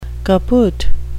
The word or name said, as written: kaput